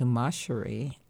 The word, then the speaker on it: Masheri: And so when I was born, she called me Masheri, but changed it phonetically to Masheri.